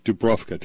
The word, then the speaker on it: Dubrovka: The hostage seizing at a theater on Dubrovka, if I pronounce that right, that was in 2002. – Dubrovka.